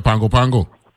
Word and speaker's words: Pago Pago: Even though you know N in it, they don't include an N in it when they pronounce it. It's spelled Pago Pago, right? They don't call it Pango Pango, yeah.